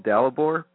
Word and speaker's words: Dalibor: Wait, if I can say it correctly, the film Diablo. How do you say that correctly? That short film you did. – You mean the last one I did? I'm actually thinking about changing the name because I find that a lot of people find it difficult to pronounce. They're not sure what it is. So, but yeah, it's called Dalibor. But okay.